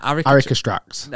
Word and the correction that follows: aristocrats: No, that's not right either. Aristocrats.